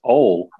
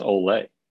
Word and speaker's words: Ole: Well, it's Ole, O-L-E. Ole, or if you're Siri, it's Ole, right? Ole. It's Ole Miss, and it's the nickname for the University of Mississippi. So, Ole Miss.